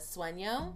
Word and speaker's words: sueño: And then we ended up just getting like an eighth of the sueño. – Yeah, I think that's how you say it. S-U-E-N-O. With a little sueño. Squiggly line above the N.